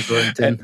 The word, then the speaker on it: Gorintin: You can say Gorintin.